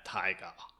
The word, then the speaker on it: taiga: I don't know how you say it. I think it's taiga. – You've got to say it like Werner Herzog. – Yeah, taiga. That's how I learned it from. I never heard anyone say the word until Werner Herzog said it in that documentary.